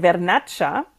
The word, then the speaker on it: Vernaccia: Ich habe nur den bekannten Weißwein von dort getrunken, den Vernaccia Ich hoffe, das ist jetzt richtig ausgesprochen.